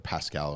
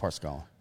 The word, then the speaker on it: Parscale: And then, you know, Parscale was put in charge of the, or Pascal or whatever the fuck you pronounce his name. I think it's Parscale.